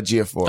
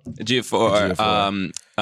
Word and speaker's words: Ejiofor: How do you say his last name? I'll mess it up. – Chiwetel Ejiofor. – Oh, yeah. Chiwetel Ejiofor.